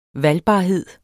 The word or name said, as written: valgbarhed